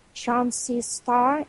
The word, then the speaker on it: Chauncey Starr: by name Chauncey Starr. Am I pronouncing it correctly? – Yes, yes.